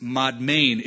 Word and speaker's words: Madmen: Now, madmen would probably better be pronounced madmain. It's not speaking of crazy men. Madmain was a city in Moab.